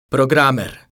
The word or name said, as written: programer